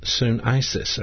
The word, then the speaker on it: sunesis: Now, the Greek word for understanding here is sunesis, or sunesis.